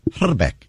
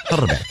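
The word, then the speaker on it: Hrbek: actual correct pronunciation of his name is Herbeck. You got to roll the I. Herbeck.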